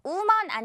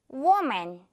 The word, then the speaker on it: woman: 이거 우먼 아니고요. 워, 워로 시작하는 거 알죠? 선생님 발음 잘 듣고 큰 소리로 따라해 주세요. woman.